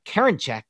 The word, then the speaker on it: Karinchak: Next, I'm going to go with James Karinchak.